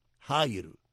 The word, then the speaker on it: Jair: Jair se escribe J-A-I-R. Es como el aire, como el viento, como el hecho.